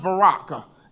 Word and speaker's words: barakah: When you look at the word blessing in the Hebrew, it's pronounced barakah. And what barakah is essentially a gift that is given to you.